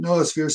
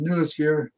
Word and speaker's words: noosphere: Some people pronounce it noosphere, some pronounce it noosphere. I've heard even people that are quite expert in this understanding of theory call it the noosphere, but I think either one is quite acceptable.